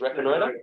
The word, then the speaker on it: Reconnoiter: Or a Connoiter, I can never figure out how it is. – It's Reconnoiter. – It's Reconnoiter? – Yes. – Yeah, lovely. That's how we pronounce it.